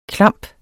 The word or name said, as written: klamp